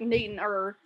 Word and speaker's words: Newton: And just on, there's a town in Massachusetts, and it's spelled N-E-W-T-O-N. Pronounce it, please. – Pronounce that. – Newton. It's the way everyone pronounces it is N-E-W-T apostrophe N. Newton. That's how you say it.